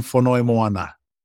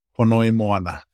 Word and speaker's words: Fonoimoana: So it's Sam Fonoimoana. Fonoimoana.